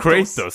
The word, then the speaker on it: Kratos: Vad fan, hur uttalar man det? Kratos eller Kratos? – Jag säger Kratos, men... – Kratos. – Kratos. Kratos säger man, just det.